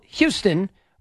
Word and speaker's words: Houston: Now, it's spelled Houston, but it is pronounced Houston Street.